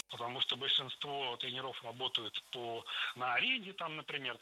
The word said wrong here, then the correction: тренеров: Тренеров, неверно. Перед нами, опять же, слово с неподвижным ударением. И двигать это ударение мы не должны. Всегда ударяем «е» в первом слоге. Тренеры, тренерам, тренеров. Похожая ситуация и у других специалистов.